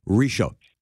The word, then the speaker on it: Ritchot: I'm going to say this properly once and for all. Ritchot. Did I say it right? – That's fine.